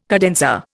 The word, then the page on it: cadenza: Indian